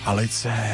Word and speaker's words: Alice: Jak se to vyslovuje? Je to Alice nebo Alice? – Tak je česká, tak asi Alice, ne? – Tak Alice. Kapela z Plzně původně.